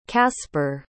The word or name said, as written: caspar